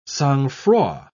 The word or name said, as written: sangfroid